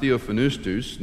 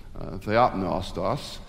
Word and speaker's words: theopneustos: Secondly, the word often pronounced theophanustos, now it's being pronounced theonustos, theopneustos, the idea that it is God-breathed.